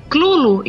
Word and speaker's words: Cthulhu: O mais próximo é Clulo e não Cutulo.